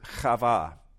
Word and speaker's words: Chava: The Hebrew name that Adam gives to Eve, that to his wife, that we pronounce today as Eve, is the Hebrew word Chava. Chava, say that with me, Chava. So it's just easier to say Eve. But it's literally Chava, which is where we get the name Eve. Eve is just an easy way of pronouncing the name Chava that Adam gives to his wife here.